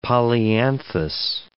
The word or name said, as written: polyanthus